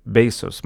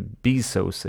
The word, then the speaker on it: Bezos: Och det är en eh, fascinerande berättelse som handlar om Bezos. Man uttalar det inte Bezos, utan det står uttryckligen att man säger Bezos.